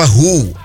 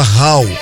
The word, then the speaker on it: Hull: Eu falava Hull, né? Uma cidadezinha que é H-U-L-L. Até depois eu descobri, muito tempo depois, que se falava Hall. Ah, meu Deus. O nome da cidade falava Hall, parece um índio falando Hall.